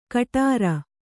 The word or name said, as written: ಗಡುರ